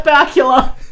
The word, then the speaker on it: Bakula: Maybe we just have Scott Bakula. Which rhymes with Dracula, and that's a scare.